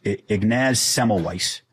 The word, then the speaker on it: Ignaz Semmelweis: And I always say his name wrong, but it's Ignaz Semmelweis.